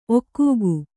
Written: ಒಗ್ಗೈ

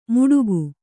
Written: ಮುಡುಗು

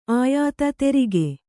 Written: ಆಯಾತ ತೆರಿಗೆ

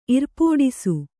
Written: ಇರ್ಪೋಡಿಸು

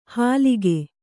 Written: ಹಾಲಿಗೆ

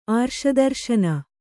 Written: ಆರ್ಷದರ್ಶನ

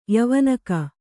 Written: ಯವಾನಿಕೆ

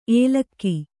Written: ಏಲಕ್ಕಿ

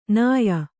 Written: ನಯ

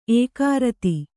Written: ಏಕಾರತಿ

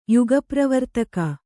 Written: ಯುಗಪ್ರವರ್ತಕ

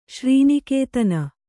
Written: ಶ್ರೀನಿಕೇತನ

